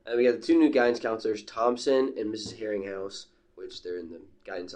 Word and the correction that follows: Herringhaus: No, it's Herringhaus. We had a meeting yesterday. That's how they pronounce it.